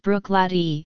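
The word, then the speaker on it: Bruichladdich: Bruichladdock, pronounced Brooklady, is located on the western shore of Loch Indol.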